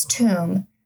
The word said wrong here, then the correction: tome: Speaking of mispronunciation, okay. Tomb? – Tome.